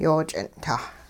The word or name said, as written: yooch'ing'-tah